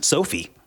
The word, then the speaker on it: SoFi: If you pronounce finance, is it actually SoFi? – Be. Or if you want to just be a little bit more casual, Ron, you can just pronounce it Sophie.